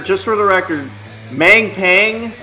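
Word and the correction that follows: May Pang: or is that how you pronounce her name? – Yeah, Mei Pang.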